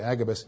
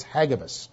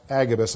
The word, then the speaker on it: Hagabus: By the way, his name is not actually Agabus, it's Hagabus. But because it's commonly pronounced Agabus, I'm going to go with that.